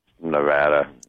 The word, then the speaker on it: Nevada: Is that how you say it, or is it not Nevada there? – Nevada. – Yeah. I think I... it is Nevada.